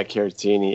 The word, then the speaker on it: Caratini: And I couldn't remember his name, but Victor Caratini, is that how you say it? – Yeah, Caratini, yeah.